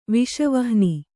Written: ವಿಷವಹ್ನಿ

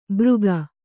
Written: ಬೃಗ

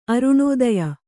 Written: ಅರುಣೋದಯ

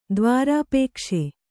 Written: ದ್ವಾರಾಪೇಕ್ಷೆ

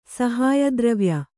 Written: ಸಹಾಯದ್ರವ್ಯ

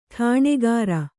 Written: ಠಾಣಗಾಱ

